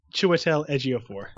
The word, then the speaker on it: Chiwetel Ejiofor: I believe it's Chiwetel Ejiofor.